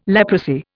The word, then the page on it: leprosy: Indian